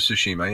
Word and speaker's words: Tsushima: I've checked out Ghost of Tsushima. If I'm saying that correctly. – Mm-hmm. Ghost of Tsushima.